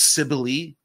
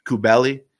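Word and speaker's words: Kybele: It's anglicized as Cybele, but I can't stand that. I like to go with the Greek pronunciation, Kubeli.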